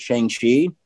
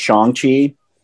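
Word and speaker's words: Shang-Chi: But anyways, he's in it and he talks about how everyone pronounces it Shang Chi, and that's not the correct way. Um, it's Shang Chi, mm-hmm.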